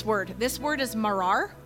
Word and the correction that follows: maror: Am I saying that right? Marar? Maror? Say it again. Maror. Maror?